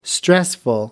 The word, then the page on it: stressful: American English